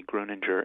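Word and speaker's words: Grüninger: You did pronounce his name rightly, Gruninger, and he's really a remarkable character.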